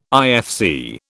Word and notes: IFC: British English

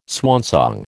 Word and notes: swansong: American English

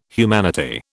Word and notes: humanity: British English

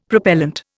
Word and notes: Propellent: Indian

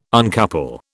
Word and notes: uncouple: British English

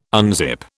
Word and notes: unzip: British English

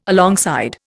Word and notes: alongside: Indian

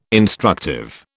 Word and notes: instructive: British English

